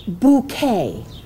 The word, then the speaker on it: Bucket: Is this the bucket residence? – Well, there are two schools of thought about that. She pronounces it bouquet. – But it's spelt bucket.